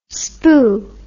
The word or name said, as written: spoo